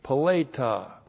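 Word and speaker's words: Paleta (פְּלֵיטָה): Paleta. P, Shiva, L, long E, T, A, H, Paleta.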